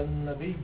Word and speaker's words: النبي: إن الله وملائكته يصلون على النبي، مش على النبي، على النبي. أكثر الخطباء في الجمعة لما يقرأوا هذه الآية ما بيقولوا بطل صحيح، ما بيقولوا على النبي بالنطق بالياء المشددة.